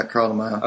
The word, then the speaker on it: Carl DeMaio: Yeah, Carl DeMaio.